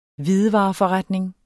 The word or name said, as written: hvidevareforretning